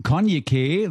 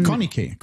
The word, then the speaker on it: Konyikeh: Konyke, würde ich sie aussprechen. – Konyke. – Ja, da ist ja so ein Y, also K-O-N-Y-I. K-E-H, so wird es geschrieben, für good, die Leute, good, die es nochmal ganz genau wissen wollen.